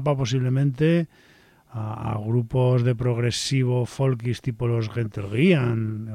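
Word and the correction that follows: Gentle Giant: o los Gentlegiant, perdón, mal pronunciado, o cosas por el estilo.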